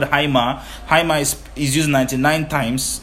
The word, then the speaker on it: haima: Alright, as we close, the word blood, like I said, is the word haima. Haima is spelled H-A-I-M-A 99 times.